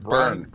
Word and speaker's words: Byrne: Byrne, I think it is. B-Y-R-N-E.